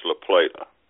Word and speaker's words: La Plata: Depends on how you wish to pronounce it. locally, it's usually pronounced La Plata.